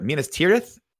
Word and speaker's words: Minas Tirith: Is it I want to say this right. Minas Tirith? – That time you said it right. – Minas Tirith.